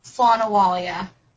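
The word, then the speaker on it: slán abhaile: Slan. Whale. Did I pronounce it right? – No. – Really? The BH makes the wuh sound.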